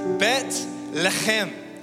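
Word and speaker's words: Bet Lechem (בית לחם): In Hebrew, the city's name is pronounced Bet Lechem.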